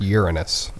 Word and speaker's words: Uranus: It's pronounced Uranus.